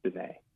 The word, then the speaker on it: Diné: they and it's D I N E. It's like this little accent mark, you know, over mm-hmm. the E, and it's pronounced Diné.